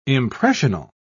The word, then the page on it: impressional: British English